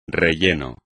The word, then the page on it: relleno: espagnol